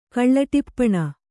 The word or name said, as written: ಕಳ್ಳಟಿಪ್ಪಣ